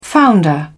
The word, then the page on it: founder: British English